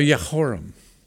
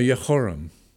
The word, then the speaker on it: Jehoram: Now, Jehoram, in Hebrew, the J is pronounced like an H. Now, Jehoram,